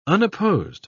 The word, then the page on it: unopposed: British English